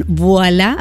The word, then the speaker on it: Voilà: Voilà, así mismo como se pronuncia, RD.